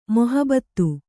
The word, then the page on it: ಮೊಹಬತ್: mohabat